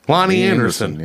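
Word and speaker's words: Loni Anderson: Lonnie Anderson.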